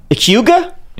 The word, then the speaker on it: hygge: Hygge? – Hygge, you know, the Norwegian. The Scandinavian concept of coziness. – What? No, tell me. Hygge, like H-I-G-G-E? – H-Y-G-G-E. – Okay. – I think pronounced hygge.